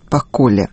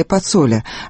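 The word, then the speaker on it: Pacolli: Им стал 59-летний Беджет Пакколе, швейцарский бизнесмен, албанец из Приштины. Правильное произношение его фамилии – Пацоле, однако в России было принято иначе.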